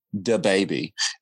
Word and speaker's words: DaBaby: Yes, I said that right. DaBaby.